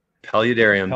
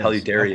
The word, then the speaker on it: Paludariums: Paludariums, is that how you pronounce that? Paludariums? – Paludariums. Paludariums. Paludaria, okay.